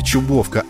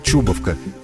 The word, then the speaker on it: Чубовка: Да, именно Чубовка, а не Чубовка. По мнению коренных жителей, ударение нужно делать не на первом, а на втором слоге.